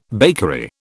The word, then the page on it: bakery: British English